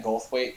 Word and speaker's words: Goldthwait: Bobcat Goldstein, or Gold, however the fuck you say his name. – Yeah, oh, Bobcat Goldthwait, yeah.